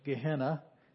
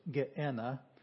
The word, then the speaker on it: Gehenna: The third one is, we pronounce it in English, Gehenna. It's in Greek, it's Geena.